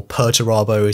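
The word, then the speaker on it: Perturabo: And the inspiration for Pertarabo is actually... There is some speculation here, because it may be slightly obvious that his name kind of sounds like the word perturbed, which, you know, no one would have guessed.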